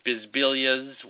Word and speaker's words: Bisbiglia: So I don't know if it's on, if you type in Bisbilia, Bisbiglia, the G is silent in my name, because in Italian names, when the L and G are together, the G is usually silent.